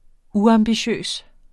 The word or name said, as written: uambitiøs